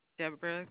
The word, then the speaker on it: Deborah: Deborah Glover. – D-E-B-R-A. – D-E-B-O-R-A-H.